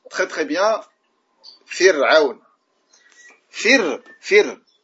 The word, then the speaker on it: فرعون: tu dis comme ça فر فر ع فر ع فرعون et non pas فرعون فرعون regarde la différence فرعون pourquoi on a tendance إلى تفخيم الراء on a tendance à prononcer را forte parce que العين elle est forte Et donc, tu vas prononcer Ra également forte. Alors que Ra ici, elle est muraqqaqa.